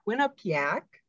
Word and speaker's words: Quinnipiac: They are going up against Quinnipiac. Did I say it right? – Yes, you are saying that correct.